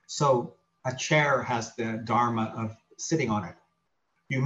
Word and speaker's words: dharma: It's pronounced dharma, D-H-A-R-M-A. There's a more common word that's similar from Buddhism called dhamma, D-H-A-M-M-A.